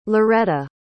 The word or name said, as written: lauretta